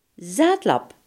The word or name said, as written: dronkaard